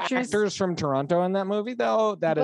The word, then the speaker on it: Toronto: And I'm like, Toronto. And anyways, apparently it's pronounced Toronto.